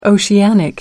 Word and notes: oceanic: American English

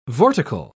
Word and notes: vortical: British English

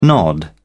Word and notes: nod off: British English

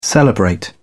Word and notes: celebrate: British English

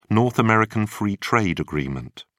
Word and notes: North American Free Trade Agreement: British English

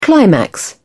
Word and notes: climax: British English

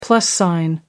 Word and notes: plus sign: British English

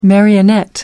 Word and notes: marionette: American English

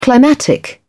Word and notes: climatic: British English

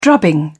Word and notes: drubbing: British English

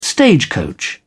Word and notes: stagecoach: British English

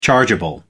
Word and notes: chargeable: American English

